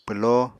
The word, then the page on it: pwló: Apatani